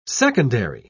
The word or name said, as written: secondary